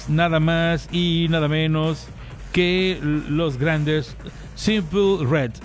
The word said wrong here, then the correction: Simply Red: Simply Red.